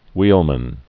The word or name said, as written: wheelman